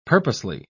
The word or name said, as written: purposely